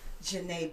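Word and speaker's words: Ja'net: Mrs. Janet Du Bois passed away. – Janae Du Bois. – I'm sorry, Janae.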